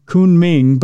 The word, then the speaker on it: Kunming: A visitor to a village outside the city of, I think it's pronounced Kunming, but it looks like coming when you read it fast, in South China, captured a video of the creepy carp, which I showed Bruiser a picture of, and it does look creepy.